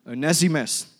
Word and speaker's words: Onesimus: Onesimus,